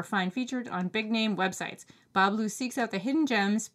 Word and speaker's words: Bas Bleu: And that's Ba Blue. It's spelled B-A-S-B-L-E-U.